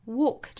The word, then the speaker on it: walk: We'll walk this road together， walk 这个词我们注意是一个紧音啊，应该是一个长元音紧元音的 all 的发音。